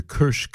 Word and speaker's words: Karsh: That is the Indian techno tabla sound of Mr. Kersh Kale. That's K-A-R-S-H. Looks like Karsh, but it's pronounced Kersh. Kersh and then K-A-L-E.